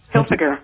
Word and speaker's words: Hilfiger: Some famous residents include Mel Gibson, Tommy Hilfiger, or how do you pronounce that, since I'm not a clothes guy? Tommy Helfiger.